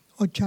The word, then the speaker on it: Öcalan: o Öcalan, ¿verdad? Se pronuncia Öcalan.